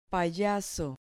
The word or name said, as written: payaso